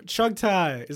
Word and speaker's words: Chughtai: Chugtai, is that right? – Yeah.